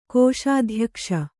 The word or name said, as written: ಕೋಶಾಧ್ಯಕ್ಷ